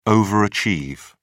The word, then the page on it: overachieve: British English